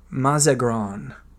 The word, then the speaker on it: mazagran: And the drink was a mazagran. – Are you sure you're pronouncing that correctly? – I am totally sure.